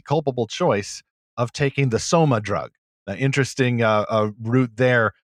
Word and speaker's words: soma: You know, you're in this condition, you're kind of in this drugged state, but also soma rhymes with coma.